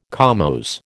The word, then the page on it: comose: American English